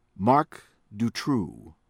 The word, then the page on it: Marc Dutroux: American English